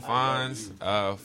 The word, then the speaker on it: Fonz: But nah, I'm on IG at No Phone Fonz. Phone is spelled with an F, you know what I mean? Fonz is spelled with an F as well, F-O-N-Z.